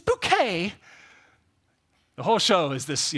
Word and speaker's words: Bucket: But there's a woman, she's like the main character of the show, basically, and her last name is spelled B-U-C-K-E-T. Anyone tell me what B-U-C-K-E-T is pronounced as? See, you said bucket, but what you don't know is it's bouquet.